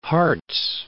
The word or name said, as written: Harz